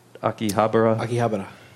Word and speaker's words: Akihabara: Akihabara.